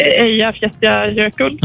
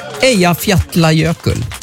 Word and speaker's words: Eyjafjallajökull: Eyjafjallajökull. – Ja, jag tror det där var ganska godkänt uttal faktiskt. Mycket bra. Eyjafjallajökull.